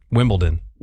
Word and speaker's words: Wimbledon: Yeah, I got D-O-N. – Wimbledon? – It's D-O-N. – Like Downton. – Okay, hold on. Say it correctly again. – Wimbledon.